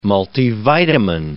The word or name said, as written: multivitamin